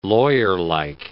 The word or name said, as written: lawyerlike